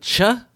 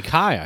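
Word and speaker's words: chi: But I'm like, is it cha? – No, it should be Kai, I think, right? – That's what I thought.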